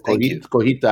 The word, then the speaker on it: Cotija: Yes, Cotija, I just said it wrong.